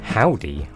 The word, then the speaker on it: Hovvdy: This is a new one from Howdy. I think you're supposed to pronounce that. It's actually H-O-V-V-D-Y.